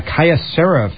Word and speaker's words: Kyocera: Kyocera phone.